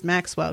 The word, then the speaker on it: Maccewill: And knowing people are afraid of saying my name when reading it, it's supposed to be pronounced Maxwell because it's actually spelled M-A-C-C-E-W-I-L-L.